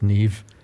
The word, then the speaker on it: Niamh: So it's pronounced Niamh, if I've got that right.